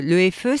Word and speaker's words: EFE: Alors vous êtes la patronne de l'EFE, c'est comme ça qu'on prononce? – Exactement. Alors, l'EFE Maroc.